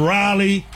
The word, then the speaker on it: Raleigh: And then Raleigh. I said it right, Kyle. Said it right, finally.